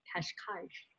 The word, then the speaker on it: Cascais: I enjoyed meeting him in Portugal at the conference in Cascais, if I can still say it right.